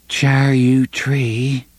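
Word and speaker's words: Charyou tree: "Char-you-tree," she said at last, pronouncing it as Roland had, "char-you-tree," with a little upturned vowel at the end.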